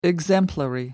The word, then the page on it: exemplary: American English